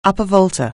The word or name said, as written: Upper Volta